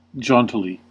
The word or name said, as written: jauntily